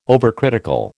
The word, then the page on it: overcritical: American English